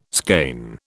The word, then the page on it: skein: British English